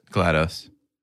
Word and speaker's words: GLaDOS: GLaDOS.